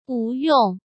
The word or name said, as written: Wu Yong